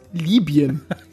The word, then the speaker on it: Libyen: Also Leute, bitte spricht es richtig aus. Libyen.